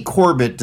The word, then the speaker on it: Corbet: He pronounces his last name Corbet. The way I used to pronounce David Mamet's name when I was growing up. Ooh, who's this David Mamet? – Like sorbet. – Sorbet. – not sherbet.